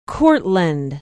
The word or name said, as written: Courtland